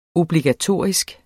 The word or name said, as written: obligatorisk